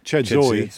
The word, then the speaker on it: Chedzoy: The rebels all fled in the direction of a village called Chedzi, to pronounce it correctly. Chedzoy, spelled Chedzi.